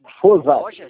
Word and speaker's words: Fosati: Agora eu fiquei curioso para saber como se pronuncia seu nome. É Fosati? – Fosati. É.